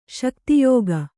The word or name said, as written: ಶಕ್ತಿಯೋಗ